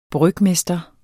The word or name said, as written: brygmester